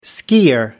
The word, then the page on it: skier: American English